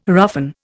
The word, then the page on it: roughen: Indian